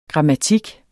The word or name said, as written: grammatik